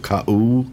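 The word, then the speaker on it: Ka-U (Kaʻū): It's spelled K-A apostrophe U. Ka-U, something. Yeah, that's how it's pronounced.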